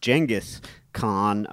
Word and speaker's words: Genghis Khan: and I've always been fascinated by Genghis Khan, or I believe it's actually technically pronounced Genghis Khan, but no one says that.